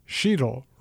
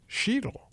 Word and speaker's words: Sheetal: And he managed to trick his electronic health record so that every time the man's name came up, it said underneath it, it spelled it, rhymes with needle. Sheetle rhymes with needle. Everyone in his office who saw this patient with a difficult-to-pronounce name who'd had a lifetime of having it mangled knew that the way he wanted it pronounced was Sheetal.